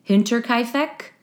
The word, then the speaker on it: Hinterkaifeck: It's the, I can't pronounce this, Hinterkaifeck? – Oh, yeah. – Is that how you say it? – Yeah.